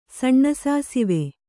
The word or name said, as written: ಸಣ್ಣಸಾಸಿವೆ